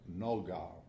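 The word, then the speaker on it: nogah (נגה): And that's that word nogah, N-O-G-A-H phonetically, for brightness.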